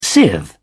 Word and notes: sieve: British English

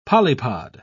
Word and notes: polypod: British English

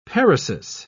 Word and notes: paresis: British English

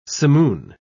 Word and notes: simoon: British English

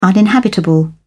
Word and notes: uninhabitable: British English

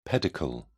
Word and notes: pedicle: British English

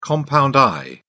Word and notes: compound eye: British English

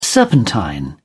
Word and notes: serpentine: British English

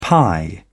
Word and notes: pi: British English

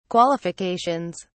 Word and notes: qualifications: British English

